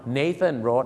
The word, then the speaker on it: Nathan: Nathan Road